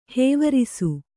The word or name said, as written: ಹೂವರಸೆ